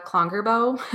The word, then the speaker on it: Klongerbo: So my name again is Sarah Klongerbow.